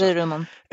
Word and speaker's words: Hagurumon: I never say his name right. Someone else say Haguramon? – The gear one. – Haguramon. – Oh. Okay, I was close. Hagurumon. And then Hagurumon.